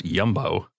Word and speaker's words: Jumbo: There's also Jumbo that's pronounced Yumbo.